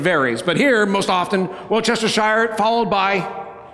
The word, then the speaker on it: Worcestershire: The accent is on the first syllable of the word. So, Worcestershire, right? Worcestershire. Or, depending on what part of England you're from, because this is a town there, right? It might be Worcestershire.